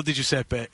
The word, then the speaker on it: Di Giuseppe: So Phil Di Giuseppe is the ultimately correct way, but doesn't have to be the way you say it, as even he goes with the anglicized version.